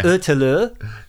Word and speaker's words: Ötillö: Ötillö. Yeah. I think it's close enough.